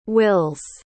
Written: wills

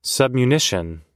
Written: submunition

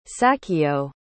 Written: sachio

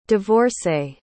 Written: divorcé

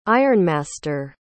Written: ironmaster

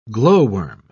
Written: glowworm

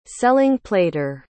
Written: selling-plater

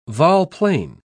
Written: volplane

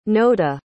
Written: noda

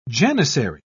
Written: janissary

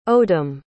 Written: odam

